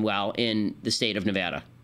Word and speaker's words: Nevada: It's pronounced Nevada, apparently. I know there are some people who pronounce it Nevada, as though they're from Great Britain and they're drinking tea.